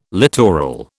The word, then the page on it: littoral: British English